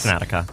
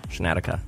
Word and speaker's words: Snedeker: Is it a sh or a s? – Brant Snedeker. – Snedeker. – You spell it like it's a S. – Snedeker. – S-N-E-D. – Snedeker. – E-K-E-R.